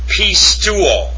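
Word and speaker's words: pisteuo (πιστεύω): In the Koine Greek, it's pronounced pisteu. Let me take that back. It's pronounced pisteuo.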